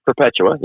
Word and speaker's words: Perpetua: Perpetua. Yes. – Okay. So tell us about her, because she's remarkable. – Yes. So Perpetua. We have something called the Passion of Perpetua and Felicity. And Perpetua, as the account tells us, was an aristocratic, educated woman.